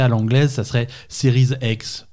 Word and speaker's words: Series: Si tu veux le prononcer à la française, ça serait Série X. – Mhm. – Tu prononces pas le S à la fin de Series.